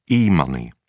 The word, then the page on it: e money: British English